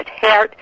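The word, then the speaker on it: Geert: Listen, the man that wrote the foreword to my book, in America they say Gert Wilders. It's actually pronounced Hert Wilders, if you care.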